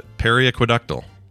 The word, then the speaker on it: periaqueductal: Periaqueductal. – I think you got it. Periaqueductal. That sounds right.